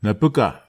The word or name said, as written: napeka